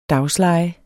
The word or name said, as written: daglejer